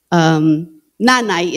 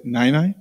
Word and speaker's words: nanay: Nanay is mother. Okay. So that would be. – Say Nainai? – Nanay.